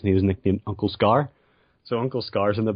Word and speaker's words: Skaarj: it's this one Skaarj. It's see, uh, the J is silent,